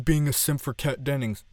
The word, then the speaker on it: Dennings: And by the way, I know for the past couple of weeks I kept on saying Kat Jennings, and I'm really, really sorry, it's Kat Dennings. So I, like, she listens to this podcast, I'm sure she doesn't. Miss Dennings, I apologize for butchering your name. It is Kat Dennings.